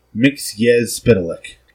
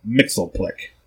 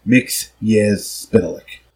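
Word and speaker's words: Mxyzptlk: Mixius Pitalik. It's not Mixelplick, as the Super Friends cartoon will try to tell us. Mixius Pitalik.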